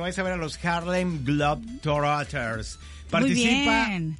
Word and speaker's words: Harlem Globetrotters: porque estaremos con los Harlem Globetrotters. O sea, ¿lo dije bien? – Harlem Globetrotters. – ¿Cómo se dice? – Glow es de globos. Globos trotando.